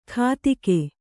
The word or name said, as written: ಕಾತಿಕೆ